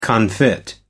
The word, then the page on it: confit: American English